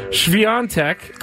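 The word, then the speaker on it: Świątek: Swiatek.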